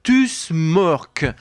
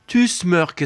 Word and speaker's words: Tusmørk: Ce groupe s'intitule, s'appelle Tusmørk. Tusmørk, ça s'écrit T-U-S-M-O, le O barré, typique des Scandinaves, qui se prononce E-R-K-E, Tusmørk.